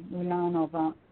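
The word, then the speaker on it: Moulin-à-Vent: Don't pronounce the T. Moulin Vin.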